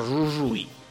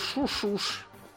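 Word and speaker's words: Jujuy: Поэтому у нас на картах его пишут на бразильский манер жужуй. На самом деле можно было так не защиряться. Сами аргентинцы его называют шушуш. – Шушуш? – Ага. У них что э, Y читается как Ш.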